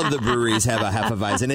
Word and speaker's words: Hefeweizen: Apparently, they have, you have to help me with this pronunciation. Is it Hefeweizen? – Yeah. Hefeweizen.